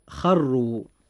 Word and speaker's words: خروا: نجد أن كلمة خروا الراء فيها مضمومة، فيجب أن تفخم أيضا عند النطق هكذا: خروا.